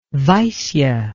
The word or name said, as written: vaisya